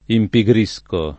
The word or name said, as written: impigrire